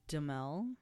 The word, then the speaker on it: Duhamel: But what's funny is, like, a younger me would have gravitated more towards Duhamel, that's how you say it.